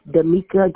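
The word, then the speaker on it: Demika: Full name is Damika Gates. – I'm sorry? – Damika Gates.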